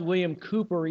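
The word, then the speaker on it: William Cowper: Years ago, an English poet I believe his name is pronounced William Cooper, even though it's spelled C-O-W-P-E-R.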